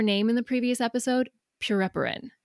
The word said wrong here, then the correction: Pereperin: Is that it? – Pereperin. – Yeah.